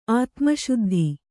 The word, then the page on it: ಆತ್ಮಸಿದ್ಧಿ: ātmasiddhi